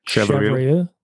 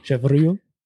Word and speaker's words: Chevreuil: Chevreuil. – Yeah. Chevreuil.